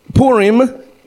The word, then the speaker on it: Purim: P-U-R-I-M, pronounced P-O-O-R-I-M. Purim, amen?